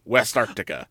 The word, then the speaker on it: West Arctica: It's not like West Arctic. It's like West Arctica.